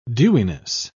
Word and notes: dewiness: British English

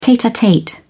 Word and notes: tete-a-tete: British English

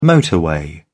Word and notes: motorway: British English

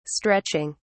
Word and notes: stretching: British English

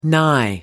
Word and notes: nigh: American English

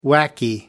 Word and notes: wacky: American English

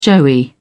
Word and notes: joey: British English

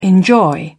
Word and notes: enjoy: British English

